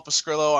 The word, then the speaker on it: Pescrilo: Find me at Paul Pescrillo on Twitter. My last name is spelled P-E-S-C-R-I-L-O.